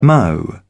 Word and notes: mo: British English

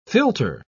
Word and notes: philter: British English

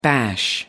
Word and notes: bash: American English

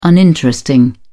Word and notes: uninteresting: British English

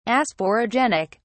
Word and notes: asporogenic: British English

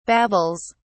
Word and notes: babbles: British English

